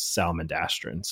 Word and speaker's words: Salamandastron: and he explicitly says salamandastron. So that's why we say it too. Is that hit, that's the pronunciation he had? But just know that those are just synonymous with each other. Obviously, it's just yep pronounced a little bit different.